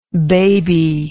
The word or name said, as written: babylike